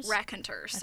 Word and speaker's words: Raconteurs: I like him with White Stripes and the Raconteurs. I don't know how to say it. – Raconteurs. – Okay. – I think that's it.